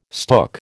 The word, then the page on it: stook: American English